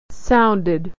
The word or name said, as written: sounded